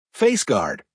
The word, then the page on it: face guard: American English